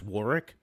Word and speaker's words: Warwick: I keep saying Warwick Davis. I think it's pronounced Warwick. – Warwick. Yeah. – But it's spelled Warwick with a W in it.